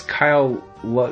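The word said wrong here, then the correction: Lyle Lovett: am I saying that right? – Lyle Lovett.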